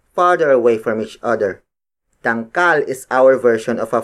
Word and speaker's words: dangkal: The next unit of native measurement is dangkal. Dangkal. Dangkal. Dangkal is spelled as D-A-N-G-K-A-L.